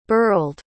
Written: burled

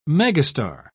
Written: megastar

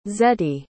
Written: zettie

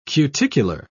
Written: cuticular

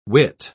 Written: wit

